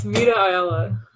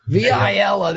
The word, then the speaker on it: Vita Ayala: It's Vita Ayala. – Vita Ayala.